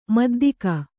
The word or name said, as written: ಮುದ್ದಿಗೆ